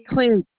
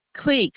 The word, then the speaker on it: Cleek: Sheila Cleek, that's me. – You spell that for me, please? – SHEILACLEEK. – Okay. And Creeks, Creek. – Cleek, no, Cleek, CLEEK, not Creek, Creek.